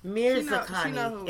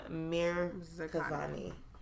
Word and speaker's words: Mirzakhani: So rest in peace, Miriam, Miss Karani. I feel like I always say her name wrong. Mirzakhani. It's not Miss Karani. Miriam Mirzakhani.